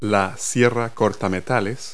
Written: hacksaw

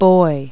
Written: buoy

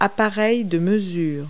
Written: measures

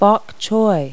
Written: bok choy

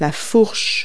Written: fork

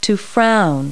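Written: frown

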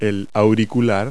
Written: earpiece

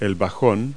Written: bassoon

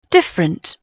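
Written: different

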